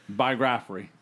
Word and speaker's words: biography: Biography. – You put the wrong emphasis on the wrong syllable. – Biography. – There it is.